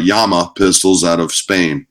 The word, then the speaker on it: llama: It's pronounced Yama, if you want to be, you know, correct about it and phonetically correct.